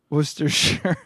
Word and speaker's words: Worcestershire: Worcestershire. – S-H-U-R. Yeah. That's how it is phonetically.